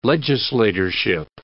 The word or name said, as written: legislatorship